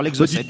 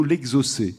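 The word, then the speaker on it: exocet: Alors, c'est vrai que ça s'écrit l'exocète, mais j'avais eu la question une fois et on m'avait fait remarquer qu'on disait, paraît-il, plutôt l'exocé.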